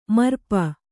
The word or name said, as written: ಮರ್ಪ